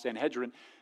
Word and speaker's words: Sanhedrin: And the reason I believe that is because it's believed that Paul was a part of the Jewish Sanhedrin. And if you're part of the Jewish Sanhedrin, actually pronounced Sanhedrin, then you would have to be married.